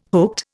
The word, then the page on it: hooked: Indian